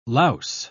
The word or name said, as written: louse